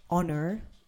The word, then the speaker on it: honor: Remember that if there is an H at the beginning of a word, except for an example like honor, H-O-N-O-U-R, that's an example, or honest, H-O-N-O-U-R. E-S-T, the rest of words which begin with H, you have to pronounce the H.